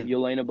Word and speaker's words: Yelena: And then that's when her sister, I don't know if I'm saying this right, Yelena, right? – Yeah, Yelena Belova.